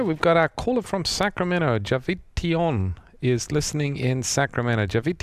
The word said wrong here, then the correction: Jatavion: Now, we want to hear you say your name because we're probably saying it wrong. – It's Jatavion. – Jatavion. Oh, okay.